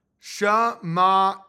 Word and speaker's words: Shema: How do you pronounce that? Shma. So you say Shema Israel.